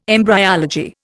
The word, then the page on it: embryology: Indian